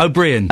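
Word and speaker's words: Ó Briain: O'Brien. – Yes, thank you. O'Brien.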